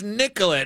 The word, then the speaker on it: Nicolet: Or when I first came here, I was like, oh, I'm going to Nicolet Avenue. And they're like, you idiot. It's Nicolet.